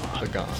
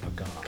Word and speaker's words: Pagán: Yeah, but with a pup, Pagán. – Pagán. – So kind of a hard G Pagán.